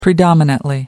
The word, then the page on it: predominantly: American English